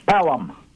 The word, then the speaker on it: Pelham: It's Pelham. – Thank you very much. Just like Pelham Parkway in New York.